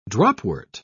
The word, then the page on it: dropwort: British English